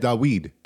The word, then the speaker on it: Dawid: So Ruski Bear, and by the way, at Garrett, in when I took Hebrew from Garrett, he said Dawid. That's how he pronounced David.